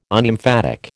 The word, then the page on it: Unemphatic: American English